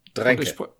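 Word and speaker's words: Mandrake: Aber die, ich glaube, das hatten wir mal vor 60 Folgen, hatten wir doch schon von der großen Mann-Drake gesprochen, oder? – Dränke. Dränke. Siehst du, ich habe das damals schon falsch ausgesprochen.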